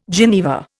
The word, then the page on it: geneva: Indian